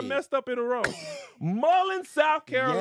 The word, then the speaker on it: Mullins: Mullins, South Carolina. – Ooh, the way you pronounce it, it's giving. – Is that the correct pronunciation? – No. – What is it? – Mullins.